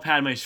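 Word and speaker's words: Padmé: And so when Padme shows up and she's like, "I'm the queen," I said that right because Padme yeah.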